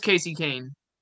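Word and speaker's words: Kasey Kahne: I think it's Kane, but there's an H in front of it. So it's either Khan or Kane or Kane. – Oh, it is Casey Kane. – Is that how you say it? – Yeah.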